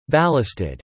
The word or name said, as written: ballasted